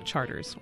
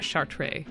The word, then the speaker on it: Chartres: But here in New Orleans, we pronounce it Charters, or Charter, but never Chartres, and not Chartres.